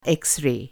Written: एक्स रे

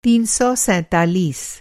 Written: तीन सौ सैंतालीस

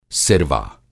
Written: सिरवा

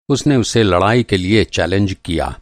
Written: चैलेंज

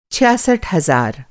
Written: छियासठ

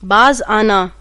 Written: बाज़

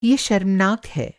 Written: शर्मनाक

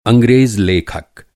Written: अंग्रेज़

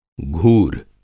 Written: घूर

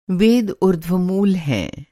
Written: ऊर्ध्वमूल

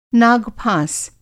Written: नाग-फाँस

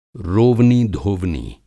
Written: रोवनी धोवनी

